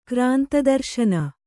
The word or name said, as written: ಕ್ರಾಂತದರ್ಶನ